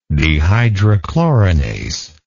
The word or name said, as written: dehydrochlorinase